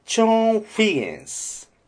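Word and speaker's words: Chone Figgins: 立ちつてとのチョーン。チョーンフィゲンス。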